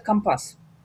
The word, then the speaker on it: компас: Это компас и компас. Мы все знаем этот классический пример. Моряки говорят компас.